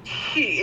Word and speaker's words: Xi: Do I say Z? – She, it's my God-given pronoun.